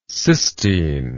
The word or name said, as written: cystine